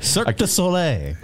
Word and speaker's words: Cirque du Soleil: Uh, it's under the, uh, Kevin Beverly, the pride and joy of Chicago, will be here talking about uh, Circus de Olay. I may have butchered, butchered that. Cirque du Soleil.